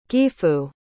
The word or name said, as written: gifu